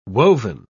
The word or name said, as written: woven